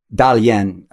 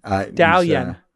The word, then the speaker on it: Dalian: Dalian is the other place? – Dalian. – Dalian. I'm pronouncing correctly.